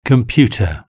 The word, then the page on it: computer: British English